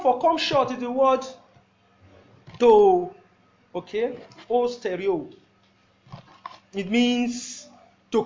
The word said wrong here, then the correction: hustereo: It's spelled H-U, okay, who? Hustereo, that is better, better pronounced. H-U-S-T-E-R-E-O. H-U-S-T-E-R-E-O.